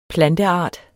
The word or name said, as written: planteart